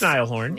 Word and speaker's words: Niall Horan: It's Niall Horan.